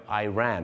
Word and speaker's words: Iran: ชาวอังกฤษจะเป็นอิรักกับอิหร่านแต่ชาวอเมริกาเยอะมากครับจะออกเสียงว่า Iraq กับ Iran นั่นเองครับซึ่งหลายคนจะนึกว่า Iran ก็คือฉันวิ่งไปไ